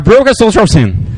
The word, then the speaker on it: Broken Social Scene: É Broken Social Scene.